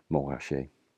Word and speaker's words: Montrachet: I remember pronouncing the T in the middle and being advised by the French wine waiter how it was actually pronounced, Montrachet.